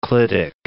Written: clitic

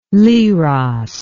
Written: Leros

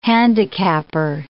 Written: handicapper

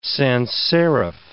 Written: sans serif

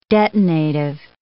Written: detonative

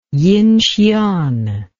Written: Yinxian

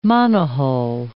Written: monohull

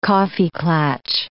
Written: kaffeeklatsch

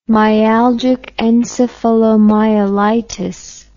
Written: myalgic encephalomyelitis

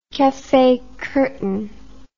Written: cafe curtain

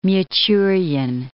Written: Michurin

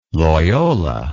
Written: Loyola

